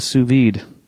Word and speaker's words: sous vide: And some dude said like, um, "How about you use a, a sous vide?" – Mm-hmm.